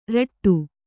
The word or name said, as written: ರಟ್ಟು